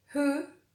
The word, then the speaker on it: who: Como en las question words, when, where o will, pero no siempre es así, porque a veces cuando la W va seguida de una H, se vuelve muda y no se pronuncia. Por ejemplo, en who, whose,